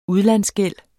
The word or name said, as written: udlandsgæld